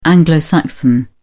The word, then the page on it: anglo-saxon: British English